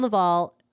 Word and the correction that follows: Laval: Sorry, Laval. – That's okay. – I have a long history of saying your name wrong.